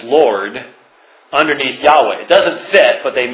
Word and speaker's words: Yahweh: Remember, Hebrew is read from right to left, so Yahweh. This is the Tetragrammaton. It could never have been pronounced Jehovah, because the Hebrew language didn't have those sounds, but in fact, Jehovah has an extra syllable.